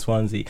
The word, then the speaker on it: Swansea: Swansea. Like, it doesn't... Is that how you say it? Swansea? – Swansea, yeah.